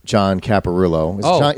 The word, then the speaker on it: John Caparulo: Right before the show, I saw that video of John Caparulo. That's how you say his name, right? – Yeah, yeah, yeah, yeah, yeah.